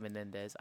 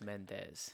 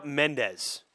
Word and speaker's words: Mendes: I always said Menendez. I didn't know it was Mendes. – Yep, just straight up Mendes.